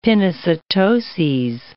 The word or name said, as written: pinocytoses